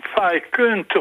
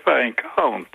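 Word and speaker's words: Viscount: Dat is ook niet Fijkunt of zo, nee, Fijkant. In hun eigen uitspraak met dat Britse stiflip gedoe.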